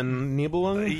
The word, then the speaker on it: Nibelung: episodic-ish uh, remake of the uh, the Nibelung rings, or how do you pronounce it? The, uh, Uh, the the Ring of the Nibelung. yeah, yeah.